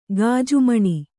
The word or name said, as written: ಗಾಜುಮಣಿ